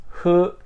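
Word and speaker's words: Huyo (冬): It's Huyo.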